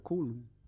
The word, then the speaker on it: Kowloon: Olha só, é, Bloodsport é, Sport é um dos poucos filmes já rodados dentro daquela cidade murada de é, Kowloon. – Eu acho que eu falei certo? Kowloon. – Eu acho que é isso, é Kowloon.